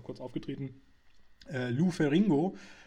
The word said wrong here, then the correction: Ferrigno: äh, oder Ferrigno wird er ja ausgesprochen, der schon ganz, ganz viele Hulks damals gemimt hat, äh, also quasi alle, in allen Hulk, Hulk war irgendwie mit dabei.